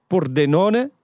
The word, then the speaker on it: Pordenone: Pordenone. – Ja, zeg ik dat goed? Dat is correct.